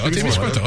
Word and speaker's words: Témiscouata: C'est-tu Témiscouata, c'est ça? – Témiscouata, c'est bon.